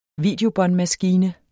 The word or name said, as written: videobåndmaskine